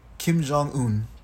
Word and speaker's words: Kim Jong-un: Kim Jong-un. – Kim Jong-un.